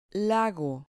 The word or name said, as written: lago